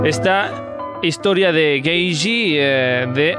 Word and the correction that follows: Genji: Genji, Genji, Lo he dicho mal, ¿no?